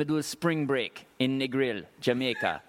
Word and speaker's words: Negril: For a white boy, white boy like me, me have to say the L really hard, really pronounce the L. Negril.